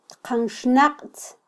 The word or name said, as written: tqanxnaqtz